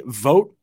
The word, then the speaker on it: Vogt: And then Henry Vogt, I believe is how you pronounce the last name, V-O-G-T, had one of the better saves that we'll see all season.